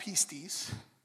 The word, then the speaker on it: pistis: The Greek word where we get the word faith from is pistis. It's pronounced pistis.